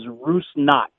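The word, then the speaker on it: Rusnák: The proper Slovakian pronunciation is Rusnak. Knock at the door. Knock on the door.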